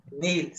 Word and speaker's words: Niel: Nil. Se, escribe Niel, pero se pronuncia Nil.